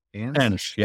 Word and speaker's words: Ansh: Ansh.